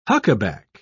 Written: huckaback

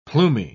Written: plumy

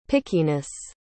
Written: pickiness

